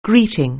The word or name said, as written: greeting